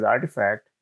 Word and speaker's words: RTFKT: In fact, Nike recently acquired a company called RTFKT, pronounced as Artifact, that built digital sneakers for your avatars to wear in the virtual world.